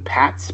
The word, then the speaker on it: Patz: I think it's Ethan Patz, P-A-T-Z. – Yeah, yes.